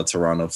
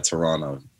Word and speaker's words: Toronto: But I mean, that's how Toronto fans are. Or I'm sorry, Toronto. I said it wrong. The second T.